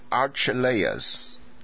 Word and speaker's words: Archelaus: 那这个亚基老的名字呢，比较不好念，美国人是把它念成 Archelaus， Harold 希律 Archelaus。